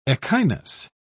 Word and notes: echinus: British English

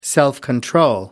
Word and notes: self-control: British English

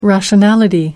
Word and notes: rationality: British English

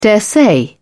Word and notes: daresay: British English

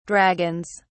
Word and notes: dragons: British English

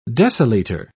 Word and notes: deciliter: British English

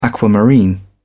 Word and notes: aquamarine: British English

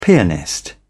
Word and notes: pianist: British English

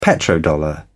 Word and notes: petrodollar: British English

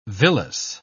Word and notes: villous: British English

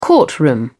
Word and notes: courtroom: British English

